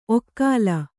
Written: ಒಕಲು

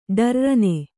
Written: ಡರ್ರನೆ

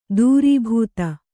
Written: ದುರ್ಬುಧ

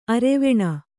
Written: ಅರೆವೆಣ್ಣು

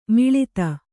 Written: ಮುಳ್ಳೊತ್ತು

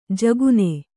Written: ಝಗನೆ

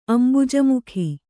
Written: ಅಂಬುಜಮುಖಿ

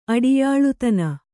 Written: ಅಡಿಯಾಳುತನ

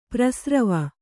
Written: ಪ್ರಸ್ರವ

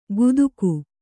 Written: ಕುತುಕು

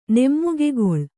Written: ನೇಮಕಗೊಳ್ಳು

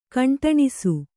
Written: ಕಂಟಣಿಸು

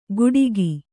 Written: ಗುಡಿಗಿ

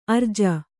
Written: ಅರ್ಜಿ

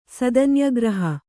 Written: ಸದನ್ಯಗ್ರಹ

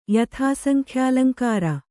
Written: ಯಥಾಸಂಖ್ಯಾಲಂಕಾರ

